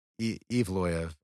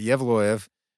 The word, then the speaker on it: Yevloyev: Yeah, the announcer, I saw one of his fights, and the announcer was butchering it, saying Yevloyev. – That's how it looks. – Yeah. But Yevloyev.